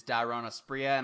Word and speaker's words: Dairon Asprilla: I believe the proper pronunciation is Dairon Asprilla, and I definitely agree with Dan.